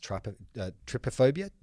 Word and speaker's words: trypophobia: I believe it's pronounced trypophobia, T-R-Y-P-O, which is the an aversion to seeing holes close together or round objects clustered together.